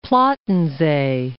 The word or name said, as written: Plattensee